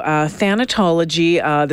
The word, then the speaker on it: thanatology: Is it thanatology? – That's correct. Thanatology is the study of death, and in a forensic context, it is, as you said, understanding the process of human decomposition.